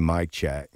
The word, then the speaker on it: Czech: And that's with a partner of mine, DJ Mike Check, like Mike Check 1-2, Mike Check, but he's actually Czechoslovakian, and that's his name, DJ Mike Check. So it's spelled C-Z-E-C-H.